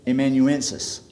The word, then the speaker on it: amanuensis: I think the proper pronunciation was amanuensis is what they had then.